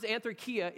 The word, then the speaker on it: anthrakia: And so, like, I taught myself by Kia, the car, that's how you announce it. Two times anthrakia is actually in all of the Old and the New Testament.